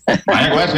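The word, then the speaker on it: Mayagüez: I can't pronounce that word. Mayaguez? – Mayaguez, yeah. That's pretty good.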